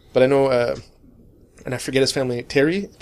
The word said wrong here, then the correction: Thierry: will be there for sure. Or Thierry? – Thierry, you see. – Pronouncing that name in English is a pain because it doesn't really exist.